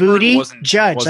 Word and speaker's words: Buttigieg: It's like, mom, Bloomberg booty judge. Like he's judging booties.